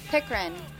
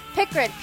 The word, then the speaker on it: Pickren: Pickren. – Oh, did Pickren. I say it wrong? – Pickren? – I think I said Pinkren. – Pickren? – Pickren. P-I-C-K-R-E-N. Pickren.